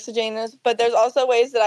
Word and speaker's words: Sejanus: However you say his name. – Sejanus? – Yeah, Sejanus. – Okay, that's what I'm hearing a lot of people say.